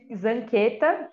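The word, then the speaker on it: Zanchetta: As pessoas conseguem me achar no Instagram pelo arroba Giri, Giri, Zanqueta, que é o meu sobrenome. Zancheta, se escreve, né? Com CH e dois T's.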